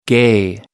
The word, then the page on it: gay: American English